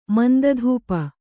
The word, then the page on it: ಮಂದಧೂಪ: manda dhupa